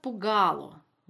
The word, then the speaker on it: Пугало: Эдуард Пугало, ударение на букве «А». Он на этом настаивал, а все остальные предпочитали ударять на букву «У».